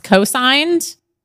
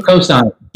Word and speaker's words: Cosynd: And there's a great company, I should learn how to pronounce their name, but it's Cosigned. Cosynd, Cosynd.